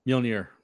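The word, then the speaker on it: Mjölnir: So she was looking at alternative healing powers and remembered, I don't know how to say it, Mew or whatever. – Mjolnir.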